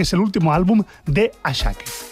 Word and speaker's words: Asake: Por cierto, el tema de los nombres Ashake, que se escribe Asake,